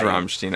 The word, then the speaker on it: Rammstein: I mean, the stupid English people call it Rammstein. But it's like Rammstein. – Rammstein. – I think it's like Rammstein. – I think it is Rammstein. – That's how you pronounce it.